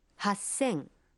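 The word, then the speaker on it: Хассен (八千): 8000… «Хассен».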